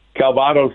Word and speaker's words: Calvados: And it's, forgive me if I'm not pronouncing it, looks like Calvados. What is that? – Calvados is that, it's the alcohol from Brittany, and it's distilled apples. – Mm. – Calvados.